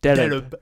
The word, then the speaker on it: derib: The word is pronounced with two syllables, derib.